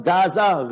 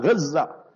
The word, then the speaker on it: Gaza: Not Uhud. Learn to pronounce the words properly. Not Gaza, Gaza. Not Uhud, Uhud.